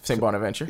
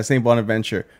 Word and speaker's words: St. Bonaventure: St. Bonaventure. – Yeah, St. Bonaventure.